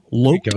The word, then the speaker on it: Loc: They wrote and produced tracks on Tone Loc's album, Loc After Dark, with a long O.